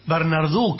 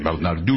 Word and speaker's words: Bernardou: Bernardú, qué buen francés. – Qué buen. – Origen francés, me parece. Bernardú. – Me gusta Bernardú. – Se escribe Bernardou.